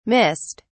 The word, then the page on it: missed: British English